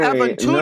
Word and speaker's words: Aventura: Well, actually, Aventura. no. If you want me to pr- pronounce it correctly, it's Aventura. But you know, I'm speaking to a white person, so I have to say Aventura.